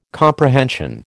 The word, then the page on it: comprehension: American English